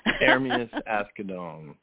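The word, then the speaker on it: Ermias Asghedom: Hermias Ascadone. Let me help you with that. I learned that in the process of working on the book. Hermias Ascadone is an Eritrean name.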